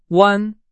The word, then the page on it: 弯: Chinese